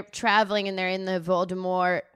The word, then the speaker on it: Voldemort: Rowling abides by this, it's pronounced Voldemort, silent T. – What? – And then the guy who read the audiobooks pronounced it Voldemort